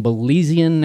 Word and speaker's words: Belizean: And holds dual British and Belizean, did I say that correctly? – You did indeed. – All right. Dual British and Belizean nationality, and he is a belonger of the Turks and Caicos Islands.